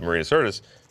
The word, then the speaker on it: Marina Sirtis: Maybe when because it like I said, it's Marina Sirtis. Am I saying her name right? – Yes.